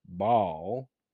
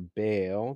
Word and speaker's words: Baal: This caused Israel to join in the worship of Baal, or Baal, depending upon how you want to pronounce it.